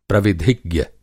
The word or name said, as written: प्रविधिज्ञ